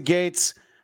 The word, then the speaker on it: Gaetz: You pronounce it Gates.